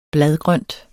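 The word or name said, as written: BLADGRØNT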